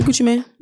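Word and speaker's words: Gucci Mane: Gucci Man.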